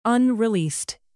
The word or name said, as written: unreleased